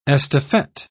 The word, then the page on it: estafette: British English